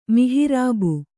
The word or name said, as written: ಮಿಹರಬ್